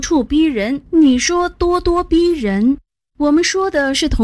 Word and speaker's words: 咄咄逼人: 哦，原来读作咄咄逼人。